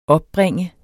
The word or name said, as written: opbringe